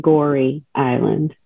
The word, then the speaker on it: Gorée: Is it Gorée Island or Gori? How do you say it? – Well, they pronounced it Gori Island.